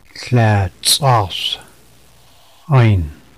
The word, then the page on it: Klatsassin: lhatasin